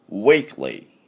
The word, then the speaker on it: weekly: ね、 k と l の間に u が入ってませんので、 weekly でありません。